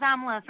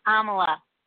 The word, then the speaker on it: Amla: Hi, this is Amla from Connecticut. – And could you say your name again? – It's Amla. – Amla. Kind of like Kamala.